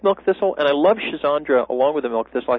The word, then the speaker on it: Schizandra: The most common spelling is S-C-H-I-Z as in zebra, S-C-H-I-Z-A-N-D as in David, R-A, schizandra. – So it's a little bit like schizandra. – Something like that, right. C-H-I-Z-A-N-D-R-A.